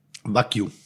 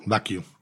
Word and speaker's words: Wagyu: Wagyu. – Wagyu? – Wagyu. – Ah, okay, da habe ich mich immer gefragt. Ich habe oder nenn es einfach japanisches Rind. – Okay, Wagyu.